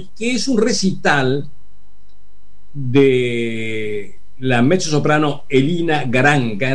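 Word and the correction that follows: Garanča: en realidad es Garancha la pronunciación, acompañado por una orquesta que dirige su marido, Karel Mark Chichón.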